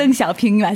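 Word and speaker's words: 등쇼평 (邓小平): 중국어 발음으로. – 등쇼평. 아닌가요? – 비슷했습니다. – 네. 이제 가짜 중국어도 진짜 중국어로 그렇죠. – 들리는. – 그렇죠. 예, 맞습니다. 그렇죠. 등쇼평이 맞습니다. 예.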